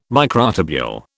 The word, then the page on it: microtubule: British English